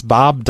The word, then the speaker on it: Bob: It's Bob Dabalina. – Bob? Can you spell it? – Sure. It's a B-O-B.